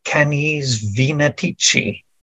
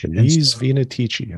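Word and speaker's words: Canes Venatici: And so according to the way it's written there, it's supposed to be Canis Venetici. – Canis Venetici.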